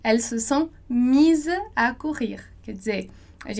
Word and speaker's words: mises: Então, lá no singular, elles se sont mises, e no plural, elles se sont mises. Então, tem um E ali, discreto, no final, que você tem que pronunciar.